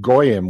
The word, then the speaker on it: Goyim: A Gaim is a mispronunciation of Goyim, which is a word for a person who is not of the Jewish faith.